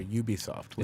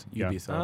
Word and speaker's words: Ubisoft: I guess we're supposed to pronounce it Ubisoft, aren't we? – We are Ubisoft. – Is that ubiquitous? – Is it really? – What? – It's like the word ubiquitous.